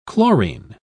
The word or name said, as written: chlorine